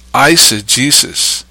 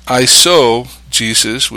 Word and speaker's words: eisegesis: Eisegesis. Eisegesis, that's spelled eise with an E, E-I-S-E, not isogesis with an O,